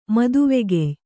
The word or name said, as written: ಮದುವೆಗೆಯ್